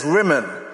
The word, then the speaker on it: Rimmon: Because actually, apparently, the gods of Syria's name was Ramanau, meaning the storm god. And Naaman deliberately changes the pronunciation around and changes some of the Hebrew vowels. So instead of saying Ramanau, storm god, he says Rimmon, so that it means when I come in and bow before the pomegranate.